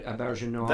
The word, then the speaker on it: Auberjonois: Aber, Auberjonois. – That is the French pronunciation.